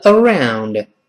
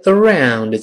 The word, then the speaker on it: around: 这个 around 注意这个发音，这个中国同学读起来有困难。这个 around 字母 r，老师说了要舌尖勾回来。好多人把这个词读成 around，around 不对哦，舌尖要勾回来，跟老师一块读 around，around。